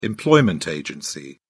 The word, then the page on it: employment agency: British English